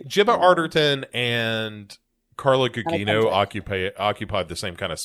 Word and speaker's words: Carla Gugino: Carla Gugino.